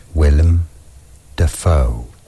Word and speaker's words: Willem Dafoe: Willem Dafoe.